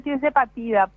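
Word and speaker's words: tirzepatida: Es tirsepatide, se puede decir en inglés, se escribe tirsepatide y en español se dice tirsepatida, pero es la misma droga.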